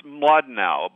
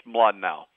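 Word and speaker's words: Mlodinow: It's Mladenow, but it's a Russian name. Starts M-L, so it's very hard to pronounce in English. Mladenow.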